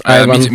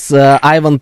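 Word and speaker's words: Ivan: Вот, и так как у меня Иванов созвучно с Иван, а прочитается это Айван. – А кто придумал это? Это придумал вот что-то Саша показывает сейчас. Айван.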